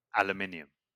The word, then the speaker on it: aluminium: So It's N-I-U-M. – But you're, what, how do you say it? – Aluminium. – Aluminium? – Yeah. You know what? I'm not going to lie. This is actually one of the few words that the US say, like pronounce differently, but they actually spell it that way.